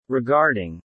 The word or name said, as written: regarding